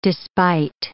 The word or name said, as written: despite